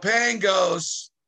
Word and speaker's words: Pangos: So in the U.S., for some reason, when it got translated, like Cosmopolitan Hotel, Cosmopolitan Magazine, they somehow, for whatever reason, English turned the G into a C. But it's really a G when you really pronounce it correctly in Greek. So Pangos is kind of short for Pangosmos.